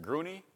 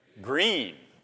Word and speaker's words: Gruene: In Gruen, G-R-U-E-N-E, how do you pronounce that? Is it Gruney, Texas, or Gruen, Texas? Green. Neither one.